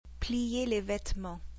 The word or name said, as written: plier les vêtements